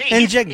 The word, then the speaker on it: Njigba: Yeah, that's how you pronounce it, Njigba.